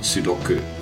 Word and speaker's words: Sudoku: Or Sudoku, as it's correctly pronounced.